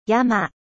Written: montagne

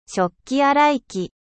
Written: lave-vaisselle

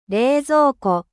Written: frigo

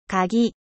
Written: cle

